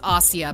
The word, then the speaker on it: Asia: A lot of people call her Asia Argento. – Right, The proper way to say her name is Asia, but a lot of people know her, I think, as Asia Argento.